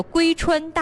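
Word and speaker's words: 妫川: 先说一下这个龟吧，这个龟字呢是一个女加一个为什么的为，很多朋友呢一看到这个字呢也知道它是属于延庆的，很多朋友就把这个字自以为的读成为，其实它是一个延庆一个独有的字，叫做龟川大地。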